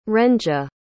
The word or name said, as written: rhenja